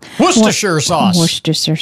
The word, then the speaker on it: Worcestershire: 1 tablespoon of molasses, 2 tablespoons of Worcestershire sauce. I always say that wrong. – Worcestershire sauce.